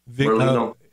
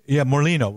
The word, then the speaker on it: Morlino: I was just with Bishop, is it Molinari? I forget how to pronounce his name. He was with us in D.C. and he spoke boldly and strongly. He passed away, I think, on November 24th. – Morlino. – Morlino, right.